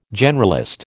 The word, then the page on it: generalist: American English